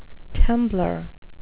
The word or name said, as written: temblor